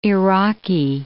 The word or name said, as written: Iraqi